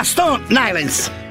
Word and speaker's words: Stone Island: Questi jeans della Stone Ainsel. – Di Jim, che c'è la questi jeans della Stone Islands.